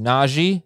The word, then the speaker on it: Najee: Najee Harris wants everyone to know that it is actually pronounced Najee Harris. Saw a clip of that yesterday, an interview he was giving. It is Najee. So, guys, let's make sure we nail that. Najee Harris, not Najee Harris.